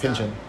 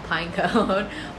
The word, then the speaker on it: Pynchon: Pynchon. – Yeah. – Pynchon, yeah. – I always mispronounce his name. I call him Pinecone. But Pinecone? – Like, yeah, but that's yeah. not it.